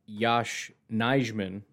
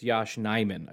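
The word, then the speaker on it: Yosh Nijman: You know, it's kind of funny because I had somebody reach out to me and explain to me that it's not Yash Nijman, like I've been calling him. It's Yash Nijman, I believe is how you pronounce his name.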